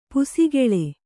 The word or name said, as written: ಪುಸುಕಲು